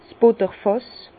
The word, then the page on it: 's Butterfàss: Bas-Rhin; Herrlisheim